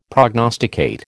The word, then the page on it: prognosticate: American English